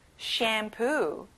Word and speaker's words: shampoo: 샴푸, shampoo. 친구들, sham이 아니라 sham이라고 발음해야 돼요. shampoo. Good job.